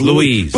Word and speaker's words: Louis: It looks like Louis, but she says Louise.